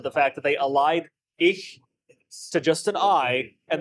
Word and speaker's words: Ich: So it's not ich, it's E. – E.